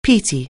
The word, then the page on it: peaty: anglais